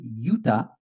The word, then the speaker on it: Utah: It is written Y-U-D-A-H. So when the Spanish... people went there, they say Utah, Y-U-T-A, Utah, Utah. That's how it came out. So it's a distortion of a Native American word that the Spanish didn't know how to pronounce. So it is Utah.